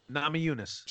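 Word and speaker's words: Namajunas: Nama Yunus. – Sorry for butchering it. – Nama Yunus.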